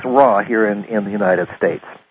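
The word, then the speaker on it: Ra: Most folks in the audience will know her through the Law of One series, the Raw material. Or is that pronounced Ray? – Well, it is in French. – Okay. – But we say Raw. – So it's Ray in French.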